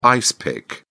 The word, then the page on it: ice pick: British English